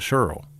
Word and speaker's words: Scurll: Did I say that right this time, Marty Scurll? He told me it's like, say girl and then Scurll. So that's what I'm doing.